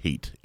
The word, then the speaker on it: Haidt: I believe it's pronounced Haidt, H-A-I-D-T, and he wrote a book not long ago, about 10 years ago.